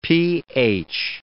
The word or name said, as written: pH